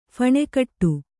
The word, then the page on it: ಫಣೆಕಟ್ಟು: phaṇe kaṭṭu